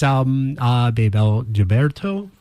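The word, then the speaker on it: Bebel Gilberto: That is Baybel Gilberto. – I'm saying it right? – Yes. – Yes. – You said it better than me.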